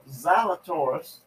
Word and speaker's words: Zalatoris: But uh, Will Sal- Zalatoris, you pronounced it right, from Plano, won his first PGA event.